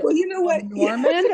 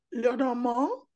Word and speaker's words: Lenormand: Because I've also heard Norman, and I never know. – You know what? Madame Lenormand is not going to rise from her grave and smack us with a ruler. So we can say it however we want.